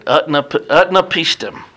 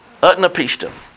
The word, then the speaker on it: Utnapishtim: Utnapishtim. Utnapishtim.